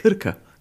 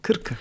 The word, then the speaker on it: Krka: Du kannst es vor allem richtig aussprechen, ich nicht. Wie sagst du? Krka, Krka. – Ja, du sprichst es schon sehr gut aus, aber das ist auch mein Fail.